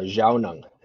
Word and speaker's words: Zhaawnong: Um, and uh, uh, th- my pronunciation is uh, Zhaawnong,